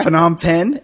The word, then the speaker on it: Phnom Penh: Yeah, Phnom Penh.